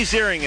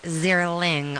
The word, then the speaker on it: Ziering: But also Ziering also gets pronounced Zierling all the time.